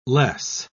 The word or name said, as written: less